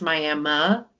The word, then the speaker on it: Miami: There's also, it's spelled the same. It's spelled like Miami, but it's pronounced Miami in Oklahoma. Because Oklahoma loves to break all the rules. – Wait, it ends in an I and you say Miami? – Mm-hmm.